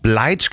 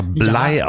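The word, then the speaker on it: Bligh: So, und wir erinnern uns an die letzte Folge, als ich über den Kapitän der Bounty gesprochen habe, den ich Bleach genannt habe, der wird aber Blei ausgesprochen.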